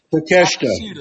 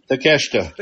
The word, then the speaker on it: Takeshita: Takeshita. – Thank you. – Takeshita.